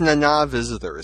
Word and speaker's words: Nana Visitor: It's Nana Visitor is how you pronounce her name, right? – Yes.